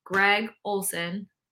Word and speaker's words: Greg Olsen: I'd say yes, but I think that Greg Olsen, did I say that right? Thumbs up, James. I think I said it right.